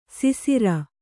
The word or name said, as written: ಸಸಾರ